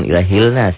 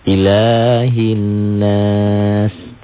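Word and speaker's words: Ilahin nas (إله الناس): Alif lamnya tidak dibaca. Jadi dari ilah, ya. Nah, ini panahnya langsung ke nun. Bacanya gitu. – Ilahin, jangan ilahilnas ya.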